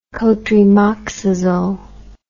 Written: cotrimoxazole

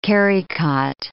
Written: carry-cot

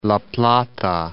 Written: La Plata

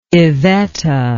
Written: evetta

